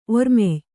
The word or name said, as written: ಒರ್ಮೆಯ್